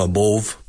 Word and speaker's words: above: So above rima con love. It rhymes. It rhymes with love. Above. Also it rhymes with dove. D-O-V-E. Dove.